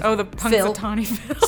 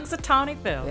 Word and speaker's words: Punxsutawney Phil: The Punxsutawney Phil. – Say it again. – Punxsutawney Phil.